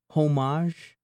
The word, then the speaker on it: homage: So to pay homage... – I think the H is silent.